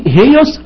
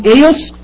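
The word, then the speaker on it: هيوس (υἱός): ايضا نفس الكتاب الدكتور جرجس بشري حنا صاد 4 في كتاب قواعد اللغة اللبنانية اللهجة العامة المستوى الاول، قال فما اقول هيوس انا ما غلطتش لان في تنفس هائي وهي تتنطق الف مكسورة، بدل ما نقول اي بقت هي، بدل ما اقول ايوس قلت هيوس عشان في تنفس هائي. خلاص، ممكن تنطق وي او اي، لكن في تنفس هائي فوقيها هنجده دلوقتي فتتنطق هيوس، مفيش تنفس هائي تتنطق ايوس.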